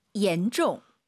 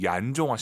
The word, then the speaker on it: 严重: 严重. – 혹시 지금 마음속으로 얀종 하신 분 계십니까? 중국어의 얀이란 발음은 없어요. Y를 I로 바꾸세요. IAN, EN입니다. 실제로 한글 이로 시작하는 발음이에요. 옌이 아니라 옌, 옌종.